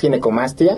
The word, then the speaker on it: ginecomastia: Para empezar, la palabra, le decía, ¿cómo se pronuncia? ¿Ginecomastía? ¿Ginecomastía? – Sí, exacto. Es ginecomastia.